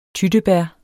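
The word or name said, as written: tyttebær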